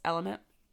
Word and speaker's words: LMNT: And it's spelled L-M-N-T, but it's Element.